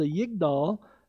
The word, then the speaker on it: Yigdal: And this is part of, it's called the Yigdal. That's how you pronounce it.